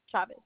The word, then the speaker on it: Chavez: Graciela Chavez with the Z. – Chavez or Travis? – Chavez. C-H-A-V as in Victor, E-V.